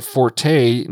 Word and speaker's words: forte: So we're going to get into blue light, which isn't really my forte, nor the forte, and I believe the correct pronunciation is fort, believe it or not, but forte it is because that's what most people are familiar with.